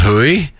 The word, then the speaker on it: Hoey: There's a place in Saskatchewan called Hooey. – I love that. – H-O-E-Y.